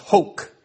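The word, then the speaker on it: hok (חֹק): This word in the Hebrew, which is pronounced hok, this word can actually mean statute or ordinance.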